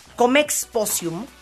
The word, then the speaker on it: Comexposium: Ahora sí que Paco Segura, director de Comexposium México. ¿Lo dije bien, Paco? – Sí, correcto.